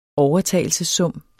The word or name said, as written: overtagelsessum